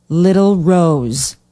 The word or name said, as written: Little Rose